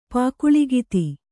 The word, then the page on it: ಪುಗುಳ್ಗುತ್ತ: kannada